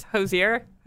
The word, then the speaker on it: Hozier: It's Hozier.